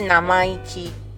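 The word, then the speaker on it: 生意気: 完了，对，然后在日文里面就有一个单字叫生意气，就做生意的那个生意，生意气它，然后它发音是 namaiki，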